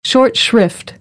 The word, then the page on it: short shrift: American English; ɔːr